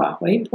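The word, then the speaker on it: Baihui: The Bahwei point, we know it's spelled funny, but it's the Bahwei point.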